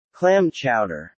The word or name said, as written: clam chowder